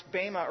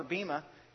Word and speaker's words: bema: And the word that's used here in the Greek is usually pronounced bema or bima.